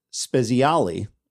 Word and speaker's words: Spezialy: Tom Spezialy, I believe is how he pronounced his last name.